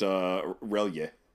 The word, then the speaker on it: R'lyeh: And you're trying to escape the island of Ridley. Ugh, I said to spell that as R'LYEH. – I always pronounced it Relia. – Relia, sure. I think that's probably more right than I.